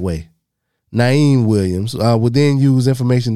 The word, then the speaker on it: Niim: The way this nigga spelled now, and I know it's pronounced Naeem because there's no other way to pronounce this name. N-I-I-M.